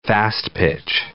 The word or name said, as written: fast-pitch